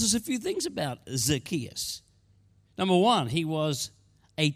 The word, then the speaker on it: Zacchaeus: People pronounce this name differently. Who calls him Zacchaeus? Would you please raise your hand? Who calls him Zacchaeus? Raise your hand. Okay, that's what I call him too tonight. I'm going to put the emphasis on that syllable and we're all good all night.